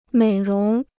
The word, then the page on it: 美容: měi róng